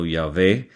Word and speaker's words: Yahvé: Fíjese que en Deuteronomio 12 los nombres divinos, Jehová, o a veces pronunciado Yahvé,